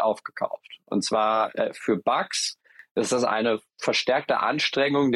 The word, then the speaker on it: Bux: Ähm, und zwar hat das äh, Amsterdamer Unternehmen Bugs, das schreibt sich B-U-X, ich schätze mal, das spricht sich Bugs aus.